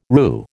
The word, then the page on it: RU: American English